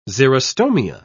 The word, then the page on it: xerostomia: British English